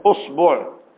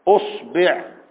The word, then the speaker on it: إصبع: فتقول إصبعيه، أصبعيه، أصبعيه، أصبعيه، زي ما تنطقها زي ما تيجي معاك، بس الصاد ساكنة في كل، الصاد ساكنة في العشر، ما جيش تبقى أصبوعه أصبيعه، لا كده تعك الدنيا، سكن الصاد وبعد كده العب في الألف والباء زي ما انت عايز.